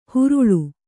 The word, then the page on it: ಹುರುಳು: huruḷu